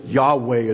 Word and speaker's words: Yahweh: He gives this name, Yahweh, it's pronounced in the Hebrew.